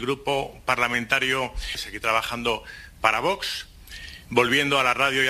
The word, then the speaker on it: Vox: Parece, oye, pero es de los pocos que pronuncia muy bien Vox. – Vox. – Claro, será porque es su jefe de prensa y sabe que hay que pronunciarlo con cierto vos.